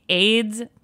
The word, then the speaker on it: Ayds: By far, the most bizarre diet trend that she ever partook in was the AIDS candy diet. Spelled A-Y-D-S, pronounced AIDS.